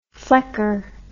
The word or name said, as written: flecker